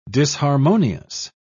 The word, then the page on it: disharmonious: British English